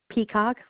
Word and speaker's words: Peacock: M-I-C-H-E-L-L-E. – Thank you. Last name? – Peacock, like the bird. P-E-A-C-O-C-K. – All right. You said P-E-K. – No, P-E-A, as in apple. C-O-C-K.